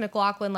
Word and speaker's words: McLaughlin: But it's pronounced McLaughlin like Sarah McLachlan.